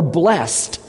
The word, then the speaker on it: blessed: It starts out in chapter 1, verse 12, the first word, blessed, or blessed. Which way is the right way to say it? They're both right, but just say it.